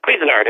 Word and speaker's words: Cuisinart: Cuisinart. It's the same as the people who make the cooking devices.